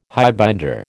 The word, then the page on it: highbinder: American English